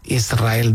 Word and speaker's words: Israel: Y la S con la R es difícil de pronunciar. La gente dice Israel. Es sin verdad. Que suene la S, Israel, sí, es difícil pronunciar.